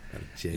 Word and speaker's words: Marcella: Marcella was a strong name, and a lot of Americans butcher their name. So every time. Marcella. – Marcella. I'm like, no, I'm not Marcella Wine. No, Marcella. – Marcella. – You know? So. – Roll the R if you can.